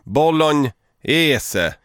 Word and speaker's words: bolognese: Bolognese. – Bolognese. Alltså att man ska uttala, man ska i Italien säger man bolognese. Så jag uttalar ju det på det italienska sättet.